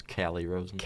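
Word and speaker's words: Calle Rosén: It's Callie Rosen. – Callie Rosen? Okay. Wow. Well, Cal is the Canadian pronunciation because now he is in Toronto. Definitely correct on that.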